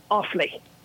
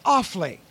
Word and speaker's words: Offaly: We're in Offaly. – Offaly. Okay, that's... – Yes. O-F-F-A-L-Y.